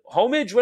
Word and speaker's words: Homage: Yeah, you know the class, the homage. What are they called? You know, they're is that homage. Homage. – Yes.